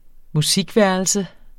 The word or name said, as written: musikværelse